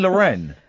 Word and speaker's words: Lorraine: You don't pronounce Lorraine Lorraine. That's Lorraine. – You pronounce the region Lorraine. – No. But it's pronounced Lorraine. You're speaking of a quiche Lorraine.